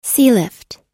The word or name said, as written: sealift